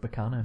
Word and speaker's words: Baccano: Let's go with Baccano. – Sure, like Meccano. – Like Meccano, exactly.